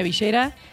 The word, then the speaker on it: villera: Muy bien, me gustaría aclarar que creo que hoy hay que decir cumbia villera. Y pronuncien bien la doble L, porque acá se dice villera y no villera.